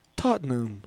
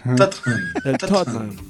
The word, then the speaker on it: Tottenham: No, ¿cómo se dice? ¿Cómo se... – El Tottenham. – Exacto. – Tottenham. Eso exacto.